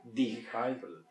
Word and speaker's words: dehydrate: Hidratar tiene H que en español no suena, en inglés sí. – Dehydrate.